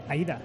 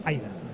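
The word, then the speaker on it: Aída: El otro día me enteré yo que no se dice Aida, se dice Aida. – Aida, con la A, no.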